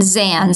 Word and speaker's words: Zands: So the best place to go is my website, which is Monica, spelled with a K, M-O-N-I-K-A, and Zands, Z-A-N-D-S, which is my last name, .com.